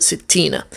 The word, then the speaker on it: ستين: The term for 60 is ستون, but because it's after في, it makes it into a different case, and we pronounce it ستينة.